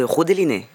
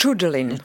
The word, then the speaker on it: Judeline: Et aujourd'hui, c'est pour la musicienne espagnole Judeline, je le dis bien. Vous dites Rodeline, peut-être. – Je crois que c'est Judeline.